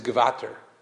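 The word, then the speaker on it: Gevatter: Because the way that the Germans pronounce it is Gevater, Gevater, which might sound like Godfather.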